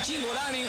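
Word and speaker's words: Cingolani: Si es italiano, debería ser Zingolani, porque la CI se pronuncia como Chi.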